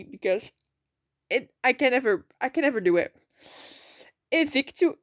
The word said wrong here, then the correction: Invicta: See, there I go. Invicta Virtuosi Racing with Jack Doohan and Emery Cordell, both not rookies.